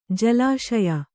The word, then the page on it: ಜಲಾಶಯ: jalāśaya